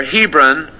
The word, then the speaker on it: Hebron: So David came, went up to Hebron, it's pronounced here, Hebron.